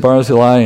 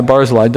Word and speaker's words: Barzillai: We first heard of this guy, Barzillai. It's tempting to pronounce it as Barzilla, but that's not what it is.